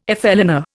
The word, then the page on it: flâneur: Indian